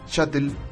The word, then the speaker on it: sshuttle: Shuttle, que se escribe como lanzadera en inglés, pero con dos S al principio, se pronuncia igual, pero su escritura es distinta.